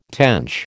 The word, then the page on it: tench: American English